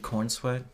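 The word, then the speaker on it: Corenswet: Yeah, David Cornsweet. – Yes, an unfortunate sounding last name. – It's not spelled like that, though. – I know. – But I'm pretty sure that's how you're supposed to say it.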